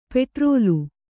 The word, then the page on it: ಪೆಟ್ರೋಲು: petrōlu